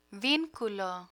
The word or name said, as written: vinculo